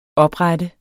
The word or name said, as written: oprette